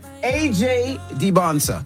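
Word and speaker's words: AJ Dybantsa: AJ DeBonsa. Did I say it right? – Yeah, you did.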